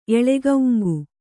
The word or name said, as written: ಎಳೆಕೌಂಗು